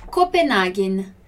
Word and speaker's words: Copenhagen: Olha a pronúncia aqui desse nosso N no final, né? Copenhagen. Ok? No italiano, quando a gente vai falar, pronunciar o M e o N, a gente tem que pronunciar como se tivesse uma letra depois ali, só que é um som mudo, né? Então é Copenhagen. Como se eu falasse Copenhagen. Só que eu não falo A, Copenhagen, ok?